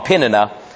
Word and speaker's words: Peninnah: It says in verse 6, because the Lord had caused her womb, her rival, this is Peninnah, I was saying her name wrong, Peninnah, her rival, her adversary, kept provoking her in order to irritate her.